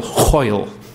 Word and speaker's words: Hoyle: hoyle, rhymes with loyal.